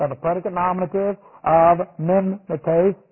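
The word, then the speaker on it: mimetes (μιμηταί): And this is M-I-M, long E, and then T, long E-S, mimetes. And we get the idea of mimic from this word. So we have M-I-M, and then a long E sound, T, and then a long E sound, S, mimetes.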